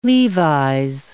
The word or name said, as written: Levi's